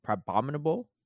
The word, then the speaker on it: Crabominable: Like, uh, wait, how do you say Crab, crab, Crabominable? – Yeah, it's kind of like it's kind of like, like abominable.